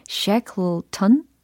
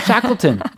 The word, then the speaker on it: Shackleton: Yeah, I'm doing my final presentation on Shackleton 요거 발음 괜찮죠? – Perfect. Yeah. – Shackleton.